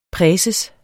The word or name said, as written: præses